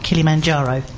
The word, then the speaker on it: Kilimanjaro: It'll be in June, and he's climbing, I'm going to say it again, climbing Mount Kilimanjaro.